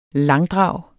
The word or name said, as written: langdrag